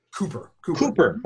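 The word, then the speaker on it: Kuper: Now, you know, I read one of the reviews that was from your website, and correct me on the pronunciation of the name, Peter Cupper? – Cooper. – Okay, Cooper.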